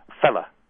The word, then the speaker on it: fellow: Fellow. – Fella. – Fellow, which we still would hear. – Yeah, and in fact, in normal colloquial speech, we maybe wouldn't pronounce the fellow, we'd just say fella.